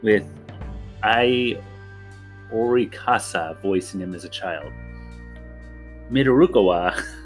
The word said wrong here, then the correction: Midorikawa: that's uh, Midor Midorikawa.